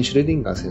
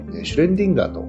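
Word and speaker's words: Schrödinger: うん、今回の配信の中で波動方程式を構築されたエルヴィン・シュレディンガー先生のことをシュレンディンガーと番組内で発音しております。これが誤りですので訂正いたします。正しくはシュレディンガー先生です。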